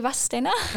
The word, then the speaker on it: Vadstena: And then Vadstena. Or as the locals say, Vadstena.